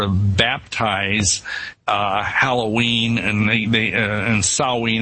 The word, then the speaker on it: Samhain: Is that the correct pronunciation? – Yeah, in the Celtic British Isles, yeah, Samhain. It's spelled Samhain, but we say it as Samhain.